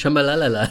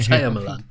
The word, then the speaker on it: Shyamalan: Man soll halt nicht Shyamalala sagen, sondern Shyamalan.